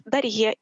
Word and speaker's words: Дарье: И я решила э, сменить свою фамилию и взяла свое имя, которое мне было дано при крещении, Дарья, просто поменяла буковку Я на буковку Е и поставила ударение. И паспорт я получала уже с фамилией Дарье.